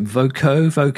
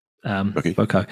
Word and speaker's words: Voceaux: Well, called, I don't even know how to pronounce it, Voco. Have you heard of these people? – I know. – And it's spelt V-O-C-E-A-U-X, as in a bit Frenchy sounding.